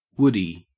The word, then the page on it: woodie: Australian English